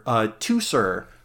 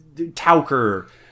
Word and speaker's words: Teucer: But also bearing mention with Telamonian Ajax is his half brother Teucer or Tauker. Again, Greek pronunciation gets kind of weird.